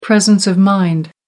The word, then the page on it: presence of mind: American English